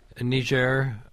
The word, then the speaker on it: Niger: Niger, I like to pronounce it that way, is the fourth largest producer of uranium in the world.